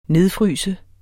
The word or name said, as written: nedfryse